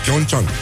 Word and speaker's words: Pyeongchang: Bonjurică, bonjurică, ce face băieții, ce mai face juniorii uh, Vești extraordinare deja de la Olimpiada de la Pyeongchang Pyeongchang se pronunță, da? Pyeongchang Toată viața am spus doar Pyeongchang și uite că acum eforturile se merită, ca să zic așa.